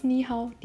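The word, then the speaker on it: Ni Hao (你好): Und nur auf Chinesisch, wenn man sich zuerst trifft, sagt man Ni Hao. Genau dieses Ni Hao, die man vor Lenz schon, schon mal gehört hatte. Ni, dritte Betonung. Und Hao, auch die dritte Betonung.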